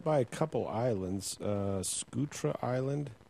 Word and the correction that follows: Socotra: Socotra is the island off Somalia.